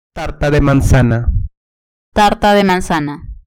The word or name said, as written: tarta de manzana